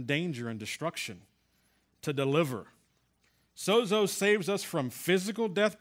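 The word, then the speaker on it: sozo (σῴζω): But in the Strong's Concordance, the word saved comes from a word sozo. Did I say that right, sozo? There you go.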